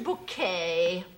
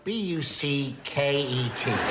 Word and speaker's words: Bucket: Uh, that's spelled B U C K E T. – Oh, bucket. oh, yes, we have a bucket. Pronounced bouquet, dear.